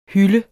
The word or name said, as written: hyl